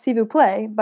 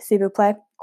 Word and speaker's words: s'il vous plaît: Is that how you say it? I'm just learning French now, because that's s'il vous plaît, but it's spelled S apostrophe I L.